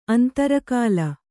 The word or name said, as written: ಅಂತರಕಾಲ